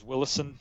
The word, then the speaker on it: Willison: It's Stephen Willison, S-T-V-E-N. Last name's Willison, W-I-L-L-I-S-O-N. I always say it's like Will is on.